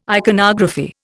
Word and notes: iconography: Indian

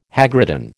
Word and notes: hagridden: American English

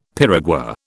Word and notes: piragua: British English

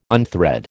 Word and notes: unthread: American English

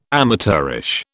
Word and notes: amateurish: British English

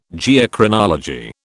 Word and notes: Geochronology: British English

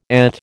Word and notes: ET: American English

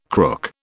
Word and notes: crook: British English

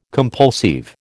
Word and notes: compulsive: American English

